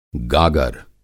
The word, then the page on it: गागर: گاگر